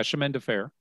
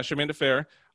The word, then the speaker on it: Chemin de Fer: They are playing Chemin de Fer. I don't know if I'm saying that right. – I think, yeah, Chemin de Fer. – Yeah, Chemin de Fer, which is essentially Baccarat, if you don't know the difference.